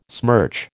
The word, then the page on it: smirch: American English